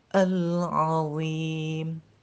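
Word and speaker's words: العظيم: Dan selanjutnya, yang harus diperhatikan dalam ayat ini, ayat kedua ini dari surah An-Naba, yakni kalimat al-azim, al-azim, azim, azim, akan salah dan akan sangat keliru, fatal kalau seumpamanya kita membacanya al-azim atau al-asim, al-ashim, itu salah, sangat salah, sangat fatal, fatal, sehingga sangat berubah artinya.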